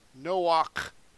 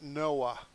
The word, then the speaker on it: Noach: All right, there is Noah, but that last letter is a k. Okay, Noach. It's not Noah. They mispronounce it in English.